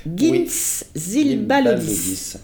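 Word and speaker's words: Gints Zilbalodis: Là, c'est pareil, prononcez le nom du réalisateur, c'est belge-france-letton, Gintz Zilbalodis.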